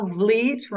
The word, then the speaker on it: Vleet: Um, and also Vleet rhymes with fleet.